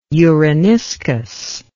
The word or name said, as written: uraniscus